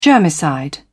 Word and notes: germicide: British English

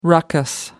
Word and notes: raucous: American English

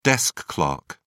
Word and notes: desk clerk: British English

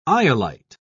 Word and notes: iolite: British English

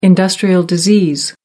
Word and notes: industrial disease: American English

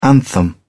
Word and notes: anthem: British English

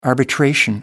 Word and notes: arbitration: American English

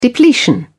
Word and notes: depletion: British English

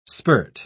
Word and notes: spirt: British English

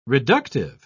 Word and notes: reductive: British English

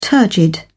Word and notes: turgid: British English

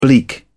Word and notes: bleak: British English

